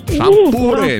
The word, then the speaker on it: шампуры: Итак, запомните, Олечка, и вы особенно, что везде, как бы вы там ни переворачивали, ударение во всех формах существительного падает на букву «у». Шампуры, металлические шампуры, жарить на шампуре, есть с шампура.